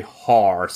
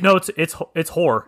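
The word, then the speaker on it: Hoare: The Iron Islands and then the Riverlands in the center were combined as one kingdom under House, I'm going to say Har, so I don't have to say Whore, because it spells... – It's Whore. – It is Whore. Okay. – That's how you say it. Right, but it's H-O-A-R-E.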